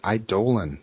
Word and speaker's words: Eidolon: That's how you pronounce it. Eidolon.